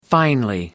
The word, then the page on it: finely: American English